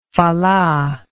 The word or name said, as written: fala